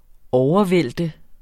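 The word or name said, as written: overvælte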